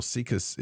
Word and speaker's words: SIECUS: Sika's is spelled S-I-E-C-U-S, circle.